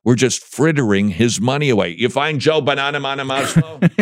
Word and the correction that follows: Bonamassa: It's Joe Banana Masa? Bana Masa. B-O-N-A-M-A-S-S-A. – Okay.